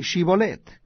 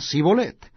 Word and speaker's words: Shibolet: Si él respondía, no, entonces le decían, ahora pues, di Shibolet. Y él decía, Sibolet, porque no podían pronunciarlo correctamente.